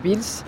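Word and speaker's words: Wiels: Et donc il y a un arrêt de tram là qui s'appelle Wils, comment tu prononces Wils? On prononce Wils, alors c'est Wils ou Wilmans, sur le...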